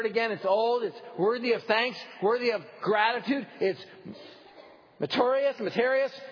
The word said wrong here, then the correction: meritorious: however you pronounce it, merit-based. Pronounce it for me, David. Meritorious, right. I got it right in the 8:30 service.